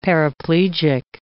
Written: paraplegic

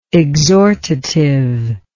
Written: exhortatively

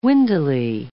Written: windily

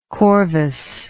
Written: corvus

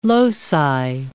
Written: loci

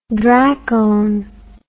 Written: dracone

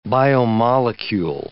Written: biomolecule

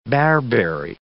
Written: bearberry

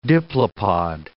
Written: diplopod

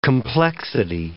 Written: complexity

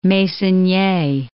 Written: Meissonier

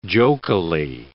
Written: jokily

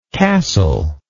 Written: cassel

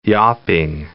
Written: yawping

